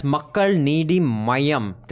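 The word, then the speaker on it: Makkal Needhi Maiam: That's Makkal Needi Mayam. Did I pronounce it correctly, Rohini? – Well done, well done.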